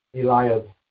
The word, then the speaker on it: Eliab: And it came to pass when they came in, they saw Ella. How would you pronounce that? Eliab.